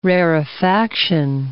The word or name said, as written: rarefaction